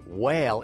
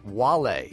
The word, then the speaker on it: Wale: And a number of comments coming in on the Soundcheck page, most of them commenting on my mispronunciation of the rapper Whale. It's actually pronounced Wale, W-A-L-E, pronounced Wale.